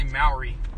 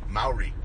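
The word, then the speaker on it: Māori: Maori. – Maori. I'm really... – You can say Maori. – Maori. – There you go.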